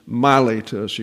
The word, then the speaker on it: Miletus: Now that's an eta there, so it's Miletus. You get a long A sound in Miletus, where I want to have a pastor's conference with you.